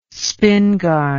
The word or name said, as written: spingarn